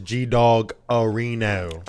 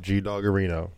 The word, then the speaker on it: G-Dawgarino: My name is G-Dog Areno. – I'm just going to call you Lur. – And you got to say it like that. Nope, G-Dog Areno. I will shut this shit down.